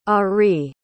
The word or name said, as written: arie